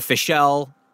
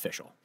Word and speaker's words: Fishel: I thought it was Danielle Fishel, but apparently it's pronounced Fishel.